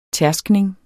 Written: tærskning

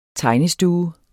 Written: tegnestue

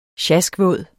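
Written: sjaskvåd

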